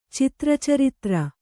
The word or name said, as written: ಚಿತ್ರಚರಿತ್ರ